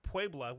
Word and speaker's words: Puebla: Which is just northwest of Puebla, which I pronounced that one right.